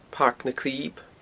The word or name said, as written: Pairc na Clib